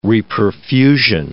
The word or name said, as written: reperfusion